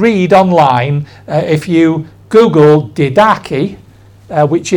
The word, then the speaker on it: Didache: The Didache, which when I used to read this, I used to read it as Didache, but it's Didache.